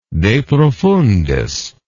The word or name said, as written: deprofundis